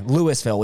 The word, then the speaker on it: Louisville: Yeah, Louisville. We pronounce our S.